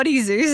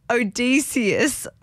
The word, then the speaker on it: Odysseus: It's not Oddie Zeus. I don't know where that came from. Guys, dyslexia's catching up to me. But Odysseus is how you say it.